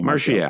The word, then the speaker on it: Martial: What is the right way to say Anthony Martial's name? – Martial. – Thank you.